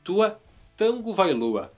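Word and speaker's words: Tua Tagovailoa: inclusive descobri nesse final de semana que a pronúncia correta é Tua Tango Vailoa. na verdade existe um N que não se nota aí, um Tango Vailoa, mas tanto faz também, isso é, é o de menos.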